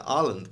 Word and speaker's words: Åland: Åland Islands. – Yeah. – Or as they. Properly pronounced, is it Ireland? – Åland. – Åland. Åland. – Åland. – Åland. I'm saying it like the football player Haaland.